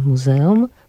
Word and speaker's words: muzeum: Przepraszam, że ja tak powtarzam to muzeum, nie dlatego, żebym się zacinała, tylko dlatego, że nauczyliśmy się jednak akcentować na trzeciej od końca, a tutaj nie ma żadnych przeciwwskazań, żeby jednak była to taka polska metoda akcentowania na drugiej od końca.